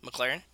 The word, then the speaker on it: McLaren: I'm going to pronounce it wrong, probably the Mechlin from? – McLaren. – McLaren is what I'm trying to say.